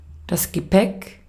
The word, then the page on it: bagage: France; [ba.ɡaʒ]